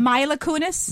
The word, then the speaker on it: Mila Kunis: Mila Kunis.